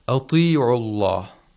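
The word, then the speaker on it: أَطِيعُوا: Ta, whenever it has the kasra or ya'ul mad, has to be pronounced with the lowest level of tafkhim. Ya ayyuhalladhina amanu ati'ullah. Ati'ullah. Okay, it's not ati'ullah. You should not exaggerate. – Exaggerate the tafkhim, and also it should not be too high.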